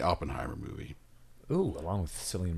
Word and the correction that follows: Cillian: Murphy or oh, Killian.